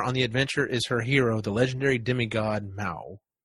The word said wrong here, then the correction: Maui: I'm probably saying it wrong. Maui, maybe? – Maui, that's it, Maui.